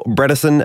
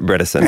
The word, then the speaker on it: Bredesen: Now, Adam, in this episode, we're going to speak to Dr. Dale Bredesen, and I've been told to remember it rhymes with medicine, which is a very good thing to know when we speak to a doctor.